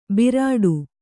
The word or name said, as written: ಬರಡು